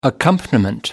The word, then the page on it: accompaniment: American English